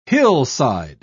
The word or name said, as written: hillside